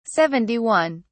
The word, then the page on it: 71: British English